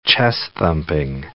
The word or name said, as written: chest-thumping